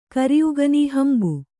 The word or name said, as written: ಹಂಬೆ